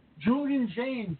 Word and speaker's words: Julian Jaynes: Julian Jaynes.